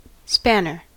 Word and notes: spanner: American English

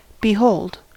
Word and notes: behold: American English

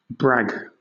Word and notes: brag: British English

